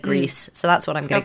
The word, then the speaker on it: Gris: So the other games in the category were Detroit Become Human, Red Dead Redemption 2, God of War, Spider-Man, and then Grease. – Is that how you say it? – Apparently. So if it was French, it would be Gris.